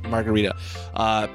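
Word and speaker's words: Margherita: I tried to have at least a slice of everything, and was it Marguerite? I think they pronounce it. – Yeah, Margarita. – Margarita.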